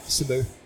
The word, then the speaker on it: Cebu: Cebu.